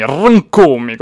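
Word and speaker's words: Rkomi: Uh, eh, io ieri ho preso da Amadeus e Fiorello che uh, lo chiama Rcomi, Rcomi con la R, Rcomi, così dovrebbe essere così, però c'è chi, non, c'è chi la fa sentire meno.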